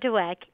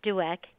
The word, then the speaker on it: Dweck: Alyssa Dweck, A-L-Y-S-S-A, Dweck, D-W-E-C-K.